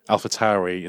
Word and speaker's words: AlphaTauri: AlphaTauri is the way to pronounce it. Actually, I learned that from going to the launch the other day.